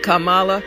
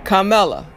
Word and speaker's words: Kamala: And Kamala, if you want to know how to pronounce their name, just remember comma, like a comma, a period, a question mark, comma, Kamala, like that. Then you don't have to worry about remembering it.